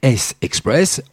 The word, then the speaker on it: S-Express: Le nom du groupe se prononce S-Express.